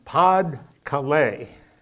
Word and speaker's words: Pas-de-Calais: So we stepped up aerial attacks on Pas-de-Calais.